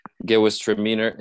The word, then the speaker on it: Gewürztraminer: So even if you don't pronounce it well, if you just say something like Gewürztraminer, or as long as it ends in meaner and it starts with G, I'm sure any wine store will be able to tell you if they have it or not.